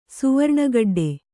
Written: ಸುವರ್ಣಗಡ್ಡೆ

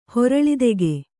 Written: ಹೊರಳಿದೆಗೆ

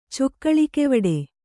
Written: ಚೊಕ್ಕಳಿಕೆವಡೆ

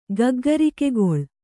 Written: ಗಗ್ಗರಿಕೆಗೊಳ್

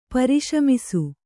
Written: ಪರಿಶಮಿಸು

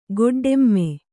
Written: ಗೊಡ್ಡೆಮ್ಮೆ

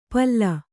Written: ಪಲ್ಲು

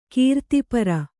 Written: ಕೀರ್ತಿಪರ

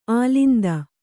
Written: ಅಲಿಂದ

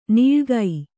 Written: ನಾಳಿಗೆ

